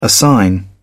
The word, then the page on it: assign: British English